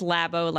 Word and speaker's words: Labo: It is Labo, like laboratory.